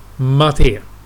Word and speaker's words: MATE: Då kan man ladda ner distributionen med KD4, Plasma 5, Gnome eller Mate, eller Mate. Hur var det man skulle uttala det? Mate.